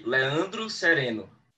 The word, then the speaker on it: Leandro Sereno: Okay, in Portuguese we say Leandro Sereno.